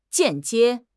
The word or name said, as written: 间接